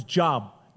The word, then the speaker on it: Job: If you've never read the book of Job, if you never knew the Bible, it's Job, J-O-B. But it's pronounced Job.